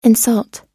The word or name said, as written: insult